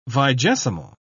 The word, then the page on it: vigesimal: British English